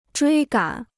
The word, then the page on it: 追赶: zhuī gǎn